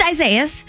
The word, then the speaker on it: Isaias: And I gotta tell you, what should be trending is the different ways that people pronounce this. It's pretty wild, but it's Isaias.